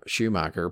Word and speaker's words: Schumacher: And I feel he's probably right that if it wasn't for Schumacher putting him, did I say that right? – Yeah, you got it.